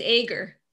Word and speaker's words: Ager: It's Ager.